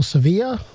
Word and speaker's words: Sevilla: or Sevilla, if you want to use the Spanish pronunciation, Caesar had been in the East and Africa.